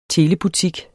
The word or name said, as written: telebutik